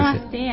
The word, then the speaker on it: Namasté: Namasté, ¿cómo se dice? – Namasté, así es.